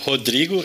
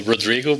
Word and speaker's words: Rodrigo: It's Rodrigo in Portuguese, but usually American call Rodrigo, but you can say in the way you want better.